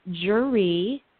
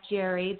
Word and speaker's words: Geri: Okay, well, first of all, I just want to let you all know that it's my the pronunciation of my name is Geri. And when you look at it, it looks like Geri. There's an accent mark, and everybody does it.